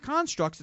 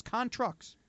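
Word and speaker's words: Contructs: It's not Constructs. It's Contrucks. – Maybe he sells trucks. – No, but it's not that spelling of trucks either. Trucks. T-R-U-C-T-S.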